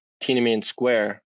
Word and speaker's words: Tiananmen Square: And then the other time, it reminds me of what I've seen on TV as a kid going on at the Tiananmen Square.